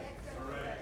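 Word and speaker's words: Varèse: Is it say Varese? Edgar Varese. How do you say that? – Varese.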